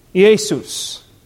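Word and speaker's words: Iesus (Ἰησοῦς): Iesus is how you would pronounce his name in the Greek.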